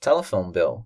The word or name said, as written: telephone bill